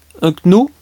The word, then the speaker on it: Unknown: Unknown, pourquoi ils mettent des K et on ne les prononce pas, ouais, c'est comme ça.